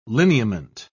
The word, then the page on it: lineament: British English